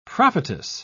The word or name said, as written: prophetess